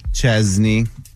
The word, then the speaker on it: Chesney: Chest, there's no T in it. – No. She doesn't say Chesney. She says like chess, the game. – Chesney. – How do you say it? – Chesney.